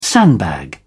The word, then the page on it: sandbag: British English